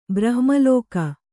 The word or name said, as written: ಬ್ರಹ್ಮಲೋಕ